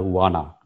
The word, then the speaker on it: Juana: Juana or Juana, J is silent in Spanish. So she is called as Juana.